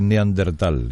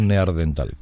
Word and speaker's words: neandertal: Porque aquí primero pronunciamos mal lo de neandertal y decimos neardental, pero porque es más fácil.